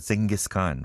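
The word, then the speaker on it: Dschinghis Khan: I guess it is, pronounced Genghis Khan, right? But it is spelled in a German way.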